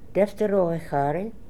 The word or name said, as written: Defteroehary